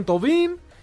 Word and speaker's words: Thauvin: Llegó ayer, presentaron a Florian Thauvin, y con eso...